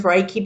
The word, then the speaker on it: Reiki: So Reiki.